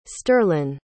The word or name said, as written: sterlyn